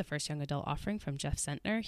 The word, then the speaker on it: Zentner: This month, we're going to be discussing The Serpent King, which is the first novel from Jeff Zentner. Is that how you say his name? – Yes.